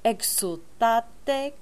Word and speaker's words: Exultate: It is an S the entire time, and make the ek sound more like an EG sound, like a hard G. Egg. Exultate, cantamos festivo.